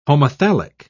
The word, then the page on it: homothallic: British English